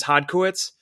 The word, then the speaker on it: Hodkowitz: I pronounce it the Americanized kind of like phonetic way, which is Hodkowitz.